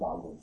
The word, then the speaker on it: Wagram: Or Wagram, as Americans pronounce it. I believe it's pronounced Wagram.